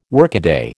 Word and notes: workaday: American English

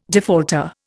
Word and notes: defaulter: Indian

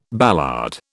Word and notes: ballade: British English

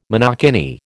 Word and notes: monokini: American English

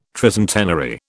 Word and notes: tricentenary: British English

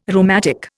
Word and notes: rheumatic: Indian

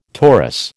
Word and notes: torus: American English

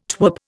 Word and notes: twerp: Indian

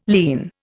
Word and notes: lierne: Indian

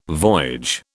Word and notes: voyage: British English

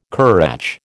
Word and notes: currach: American English

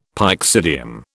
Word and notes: pyxidium: British English

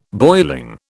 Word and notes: boiling: British English

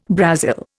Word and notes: Brazil: Indian

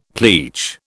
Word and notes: pleach: British English

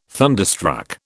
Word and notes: thunderstruck: British English

